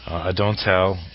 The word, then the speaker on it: Adonthell: Adonthell, it's spelled A-D-O-N-T-H-E-L-L, but according to the fact, you can pronounce it any way you want, but some of the recommended suggestions are Adonthell, and the H is silent in there.